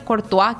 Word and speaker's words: Courtois: El apellido del arquero belga Cortiz se pronuncia en español Courtois, que es lo que nos estaba diciendo también.